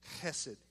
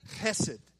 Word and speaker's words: chesed: Say that with me, chesed. If you did not spit on the person in front of you, you are not saying it correctly, okay? It's chesed. And hesed, what hesed means is it's this all-pursuing, steadfast love.